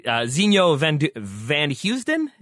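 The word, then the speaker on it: Zinho Vanheusden: Zinho Van Heusden? Is that how you say it? – Yeah.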